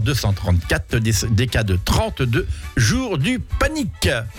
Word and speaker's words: panic: On dit, ah, la panique, non, là, c'est le panique, le panique. Ça s'écrit P-A-N-I-C.